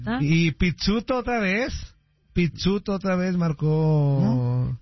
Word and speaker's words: Pizzuto: Se pronuncia como pizza. Pizzuto.